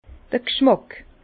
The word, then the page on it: d'r g'schmàck: Bas-Rhin; Herrlisheim